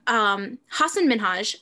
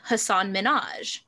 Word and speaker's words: Hasan Minhaj: he decided that I want my name pronounced correctly, because a lot of people would call him Hasan Minhaj. He's like, that's not how it's pronounced.